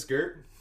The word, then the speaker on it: Gert: My name is Gert. It is a one syllable interpretation of my actual name, which is Garrett, but honestly I prefer Gert, so let's just go with Gert.